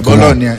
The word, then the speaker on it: Bologna: ¿Bologna o Bologna? – Sí. No, GN se pronuncia. – Ah, ya, Bologna.